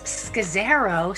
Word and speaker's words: Scazzaro: First of all, I have to say how impressed I am, Kathy, that you pronounce Scazzaro so beautifully.